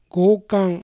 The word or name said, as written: Gokan